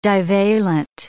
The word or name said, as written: divalent